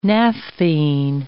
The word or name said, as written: naphthene